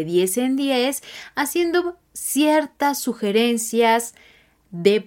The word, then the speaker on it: deux: Con el número 2 es DE, DE, D, E, U, X. No pronunciamos la X en este caso, pero tenemos que hacer el sonido de E.